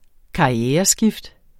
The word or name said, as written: karriereskift